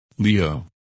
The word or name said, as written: Leo